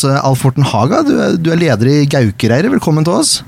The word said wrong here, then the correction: Gaukareiret: Uttalte jeg Gaukereiret nå riktig? – Ja. Vi sier vel litt mer rundere kanter, så vi sier Gaukareiret.